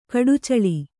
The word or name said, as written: ಕಡಚ್ಚಿಲು